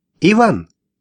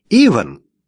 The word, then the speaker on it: Иван: «Иван!» — неожиданно позвала сзади Джулия. «Иван!» Как всегда, она сделала ударение на «и».